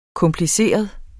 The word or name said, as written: kompliceret